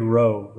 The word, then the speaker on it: Roh: So it's it's Craig Roh, right? I'm saying that right? – Yeah. Okay. Yeah, C R A I G R O H.